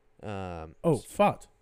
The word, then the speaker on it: Pfaadt: Obviously, through like some of their better guys, Gallen, and I don't know how to pronounce that guy who started the first game for them. It's like P-F-A-A-D-T. – Fought.